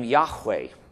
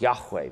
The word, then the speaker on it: Yahweh: Now, scholars who have studied this, people like Ron Allen and others at Dallas, are very convinced that the name Yahweh, going back and looking at the ancient documents, that this is, if this is not the pronunciation, it is really close to the pronunciation of the name of God, Yahweh.